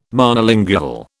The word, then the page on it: Monolingual: British English